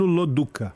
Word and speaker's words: Loduca: Agora, me ensina a pronunciar o seu sobrenome. – É Bruno Loduca. – Ah, Loduca, ok. Loduca. Do jeito que escreve, então.